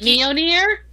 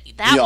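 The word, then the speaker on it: Mjölnir: Yeah, I start with an M and then go to an LN and then end with an R. – It's kind of like Mjolnir.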